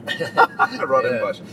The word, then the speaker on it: Rondebosch: How do you pronounce it? Rosen. Ron Rondebosch. The Dutch word for round bush. Rondebosch.